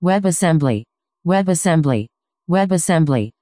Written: webassembly

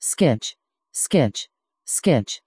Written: sqitch

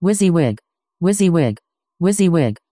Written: wysiwyg

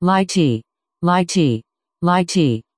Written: lighttpd

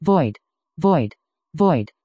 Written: void

